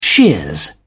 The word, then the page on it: shears: British English